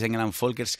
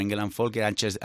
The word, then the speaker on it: Engel & Völkers: Si dices Engel & Völkers, que es como se pronuncia en alemán, nadie sabe dónde trabajas. Entonces hay que decirlo un poco mal, como Engel & Völkers, Ángeles de Charlie, cosas así.